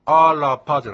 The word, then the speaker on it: アラパ: ですからアラパじゃなくてアラパというような感じで、 R ですから飲み込む感じですね。